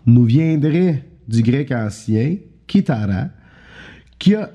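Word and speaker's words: Kitara (κιθάρα): Mais euh, le mot latin, lui, il vient du mot euh, grec Kitara. Écoutez bien que c'est avec un C qui prononce, un K, je veux dire, qui prononce leur C.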